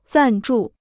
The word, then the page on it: 赞助: zàn zhù